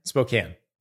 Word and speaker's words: Spokane: Is it Spokane? – Spokane. 90 minutes. – There's no reason for the E on that word. – We have a lot of silent E's in the English language. It's okay. – That silent E is tricky. You can at least admit that that's a tricky silent E.